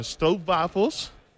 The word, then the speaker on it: stroopwafels: So, so these are stroopwafels.